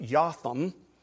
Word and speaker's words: Jotham: And there's so much irony in all of this, in his name, because his name, Jotham, in Hebrew you would pronounce it Jotham, which means Yah, as in Yahweh, God, is morally blameless.